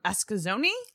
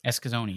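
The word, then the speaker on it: Eskasoni: You are from Escazoni? – Escazoni, yeah. – I said it right.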